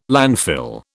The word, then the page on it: landfill: British English